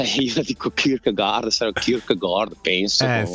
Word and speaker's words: Kierkegaard: che è Kierkegaard, giusto? Più o meno la pronuncia l'ho indovinata. – Sì, Kierkegaard, ma poi in tedesco sarebbe, ma comunque ci siamo.